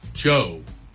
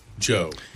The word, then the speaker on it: DJO: Joe. The D silent. Joe. God, why can't you put J-O-E? It's Joe.